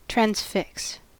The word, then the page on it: transfix: American English